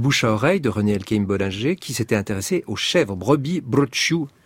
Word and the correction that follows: brocciu: Nous on dit le brooch, mais parce que la fin du mot ne se prononce pas, c'est comme vous dites pas Porto Vecchio, vous dites Porto Vecchio.